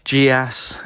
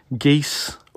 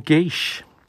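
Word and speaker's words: geas: It is actually a quite direct Gaelic borrowing, which is more correctly pronounced geese or geesh.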